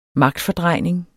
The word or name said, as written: magtfordrejning